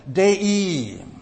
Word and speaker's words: dei: dei. Some of the scholars and preachers pronounce it differently, but that's the way that I pronounce it.